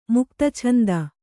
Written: ಮುಕ್ತಛಂದ

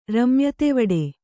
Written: ರಮ್ಯತೆವಡೆ

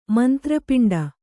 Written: ಮಂತ್ರಪಿಂಡ

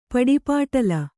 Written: ಪಡಿಪಾಟ್ಲು

